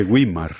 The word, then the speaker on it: Güímar: Permíteme que recuerde a nuestros oyentes que has nacido en la localidad de Guiñar. – De Güímar. – De Güímar, perdón. – Sí. – En las Islas Canarias. Es difícil de pronunciar. – Sí, es muy difícil porque tiene diéresis.